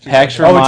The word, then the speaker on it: Pax Romana: Our next bit of tidbit news, Sci-Fi is developing a Pax Romana series. Did I pronounce that right? – Romana, yeah, miniseries.